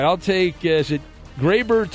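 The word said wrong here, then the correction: Gleyber: Torres? Is that how you say it? I think. Yes, I'll take him from the Yankees. And also. – Glaber, I believe. – Glaber, I was close.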